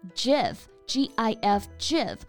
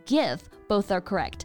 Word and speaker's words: GIF: 它其实是 Graphic Interchange Format 的缩写，也就是可交换的图像文件，那它的正确读法啊，我们可以读成 GIF，G I F GIF，或者是 GIF，both are correct。但是并不是我们中文当中拆开来念 G I F 这个样子啊。 – This one can be a bit controversial. Some people pronounce it with a hard G, like gift. while others pronounce it with a soft G, like GIF.